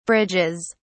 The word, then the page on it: bridges: British English